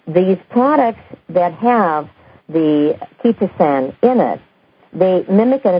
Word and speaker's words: chitosan: It is C-H-I-T-O-S-A-N, and it's pronounced ketosan. But it's C-H-I-T-O-S-A-N.